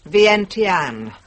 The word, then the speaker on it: Vientiane: Vientiane, capital of Laos.